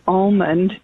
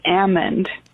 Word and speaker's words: almond: The L is silent. So it's not even really almond, it's almond.